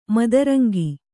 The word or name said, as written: ಮದರಂಗ